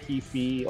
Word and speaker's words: Heafy: Yeah, I know you love Matt Heafy or Heffy, whatever you pronounce his last name. – Yeah, you were right the first time.